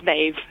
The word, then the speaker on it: Maeve: My name is Maeve. – Maeve? – Oh, Maeve. – Maeve. – Oh, Maeve. – It rhymes with Dave.